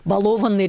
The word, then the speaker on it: балованный: Ну, практически никто, да, ну, филологи стараются говорить так, «балованный ребенок», да, но по сути... Он балованный, да, и от этого, если мы вдруг ударение поменяли, ну, ребенок послушнее не стал, да, если честно.